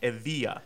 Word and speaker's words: Evija: It's pronounced it's spelled E-V-I-J-A, but according to Lotus, it's pronounced Evija.